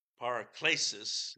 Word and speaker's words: Paraklesis: Paraklesis is the way that it would be pronounced.